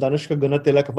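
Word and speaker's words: Danushka Gunathilaka: Danushka Gunatilaka, firstly, full marks to you for the pronunciations.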